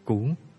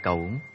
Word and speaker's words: cẩu: Cú có thể đọc là cẩu, là cái nơm.